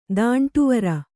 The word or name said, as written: ದಾಂಟುವರ